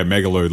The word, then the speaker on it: Megalude: Yeah, Megalude, like interlude.